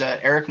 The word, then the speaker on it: Erik: You know, you can go to, well, my story, my site is Eric Michael Blog. My name is spelled E-R-I-K.